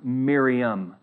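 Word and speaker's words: Miriam (Μαριάμ): Now, in Greek, the other usages of the name Mary are like Maria. It literally sounds that way in Greek, Maria. But this one, and the one later in the text, is literally pronounced Miriam.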